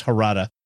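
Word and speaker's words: Jarada: it's spelled with a J, but it's Harada.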